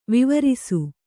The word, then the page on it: ವಿವರಿಸು: vivarisu